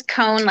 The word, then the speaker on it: Kohn: And my last name is Kohn, like ice cream cone.